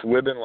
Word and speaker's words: Wibben: It's Wibben, like ribbon.